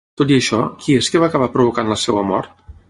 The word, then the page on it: provocant: Catalan